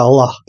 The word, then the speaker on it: Аллах: Ну да, да, оно Аллах, Аллах, да, Х более мягкая. но ты говоришь на русском языке, и в русском языке пишется так, понимаешь, пишется Аллах.